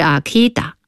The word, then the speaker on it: Akita: 秋田的英语发音是 Akita，所以叫了阿 K。